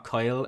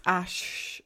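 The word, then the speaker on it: Æ: It's not Kyle. I think it's pronounced Ash. – Yeah, it's Ash.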